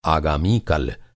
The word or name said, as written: आगामी